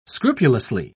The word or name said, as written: scrupulously